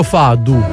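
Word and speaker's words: fado: Sembrava più un pezzo fadu. Quindi. – Fadu? – Sì, perché era la pronuncia portoghese.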